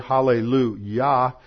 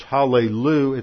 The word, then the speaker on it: hallelujah: When it's hallelujah, it's a second person plural form meaning you all praise. See, they were from the south. Y'all praise. And then Yah, the last syllable, is the first syllable of the name of Yahweh.